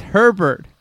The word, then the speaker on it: Herbert: And he said, his name is Herbert, pronounced as it's spelled. He's Australian, not French.